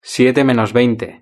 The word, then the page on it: siete menos veinte: European Spanish